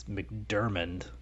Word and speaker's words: McDormand: And just a quick note, I was gonna let it slide, but both of you said Frances McDormand. – McDormand. – Is that not how it's pronounced? – No.